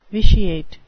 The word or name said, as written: vitiate